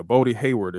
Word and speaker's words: Bodie Hayward: Or am I saying his name right? I think I am. Yeah, he's saying it right. Yep. – Okay, yeah. Bodie Hayward, if you don't know who he is, he was pretty much the right-hand man of Andre Chase and Chase U.